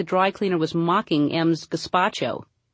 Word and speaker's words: gazpacho: For the first 20 minutes we ate in silence, with the exception of the dry cleaner remarking this gazpacho is heavenly. He pronounced gazpacho with a soft G. Gazpacho. Not a hard G, the way it should be pronounced.